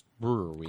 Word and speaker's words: Brouwerij: We have been wanting to go to Brewery West, spelled Brouwerij. – And on their site, it says pronounced Brewery.